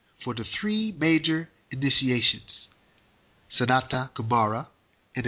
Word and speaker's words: Sanat: I'm sorry, Sanata. It's spelled S-A-N-A-T, Kumara, K-U-M-A-R-A. All right?